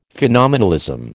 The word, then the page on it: phenomenalism: American English